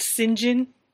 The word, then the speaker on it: St. John: And I just found out this morning it's pronounced St. John.